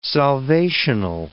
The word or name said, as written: salvational